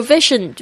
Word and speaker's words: vision: 要发这个 Z 的声音， Television 也是这个发音。 Your vision Vision 就是你的一个愿景，你的想象力，你对未来的一种幻想，就叫 Vision Vision，它有时候也翻成一个人的远见， Your vision。